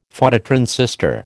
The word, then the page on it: Phototransistor: American English